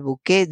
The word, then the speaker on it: buqué: hablaban del bouquet de un vino, como suena, B-U-Q-E con tilde, el bouquet de un vino. Es perfectamente correcto, es la adaptación del francés, bouquet, solo que en francés se escribe bouquet, se ha adaptado al castellano como bouquet.